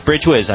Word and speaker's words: Brijwhiz: That was from Bridgewiz, I believe it's pronounced. B-R-I-J-W-H-I-Z.